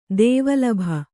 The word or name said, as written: ದೇವಲಭ